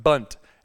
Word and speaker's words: bundt: And she's having a hard time pronouncing the word bundt, because there's the NDT sound, bundt.